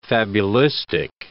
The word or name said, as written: fabulistic